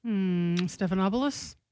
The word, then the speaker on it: Stephanopoulos: Stephanopoulos? – Is that how you pronounce it? – Yeah. – It's not Snuffleupagus? – No.